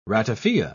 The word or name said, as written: ratafia